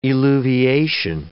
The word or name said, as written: eluviation